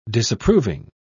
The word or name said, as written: disapproving